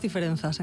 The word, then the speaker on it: Diferenzas: ¿Con S o con Z? ¿Cómo se pronuncia? – Con Z. Diferentes Diferenzas en galego.